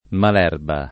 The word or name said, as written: malerba